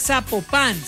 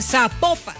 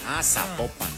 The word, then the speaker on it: Zapopan: Oiga, nos escriben los oyentes y que no se pronuncia Zapopan, sino que se pronuncia Zapopan. – Zapopan.